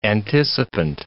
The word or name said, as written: anticipant